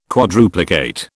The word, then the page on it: quadruplicate: British English